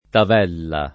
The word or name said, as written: tavella